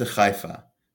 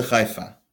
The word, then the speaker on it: Haifa: A ship to Haifa. Oniya lechefa. The difference between Haifa and Haifa is Haifa is the Arabic pronunciation, which most Israelis use, but Haifa is the proper Hebrew pronunciation.